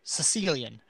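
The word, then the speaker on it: caecilian: They're called, now I believe I'm pronouncing this right, it's pronounced Sicilian, but it's C-A-E instead of how you would think, like, you know, Sicily.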